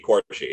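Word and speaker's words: Quarshie: Him and was it Hugh Quarry? Butchering his name. You know, Captain Panaka. – Quarshi, yeah.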